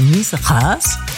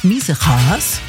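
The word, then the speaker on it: Miesegaes: Ich habe mal gehört, ein G spricht man im Niederländischen. Also Miesgaas.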